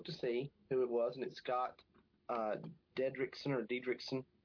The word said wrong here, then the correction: Derrickson: And it appears Derrickson, Scott Derrickson, I'm sorry, I mispronounced his name.